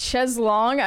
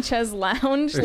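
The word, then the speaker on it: chaise longue: Another person said, it's pronounced cheslong. I've been out here my whole life saying cheslounge like a peasant.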